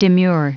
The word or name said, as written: demure